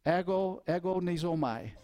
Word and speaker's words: agonizomai: A-G-O-N-I. In the Greek, that's pronounced agonizomai. Agonizomai.